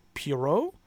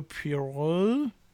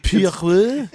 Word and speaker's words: Pierrot: Let's see, Studio Pierrot. – Yep. I'm sure. I don't think it's pronounced in a French way. – Pierre. – It's like, no, Detective Poirot.